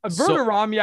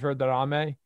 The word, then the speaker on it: Verderame: Verderame, I can live with, considering like that is actually how it's supposed to be pronounced, Verderame.